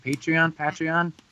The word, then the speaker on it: Patreon: I think I pronounced it right. Patreon.